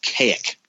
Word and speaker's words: Kazik: It's so weird, because in the book he writes it out K-A-Z-I-K and then makes sure to tell you it's pronounced Kaik.